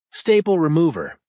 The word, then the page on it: staple remover: American English